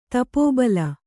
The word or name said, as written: ತಪೋಬಲ